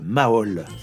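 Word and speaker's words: Mhaol: C'est le groupe Maol. Ça s'écrit M, parenthèse H, fermez la parenthèse AOL, A-O-L.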